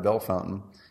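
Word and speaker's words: Bellefontaine: He goes, you're talking about Belle Fontaine or whatever it was. Yeah. He said, you're talking about Belle Fountain, right? I'm like, I don't think so, dude. That's not how it's spelled. And he goes, no, it's Belle Fountain.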